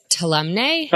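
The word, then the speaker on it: Tuolumne: I'm always mispronounced this. I have a mental block with it. Tolume Meadows. – I think it's Tolumne. – Tolumne, you're right.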